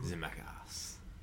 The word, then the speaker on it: Zemeckis: Zemeckis.